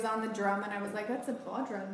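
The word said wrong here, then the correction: bodhrán: right?" – No, it's a Bowron.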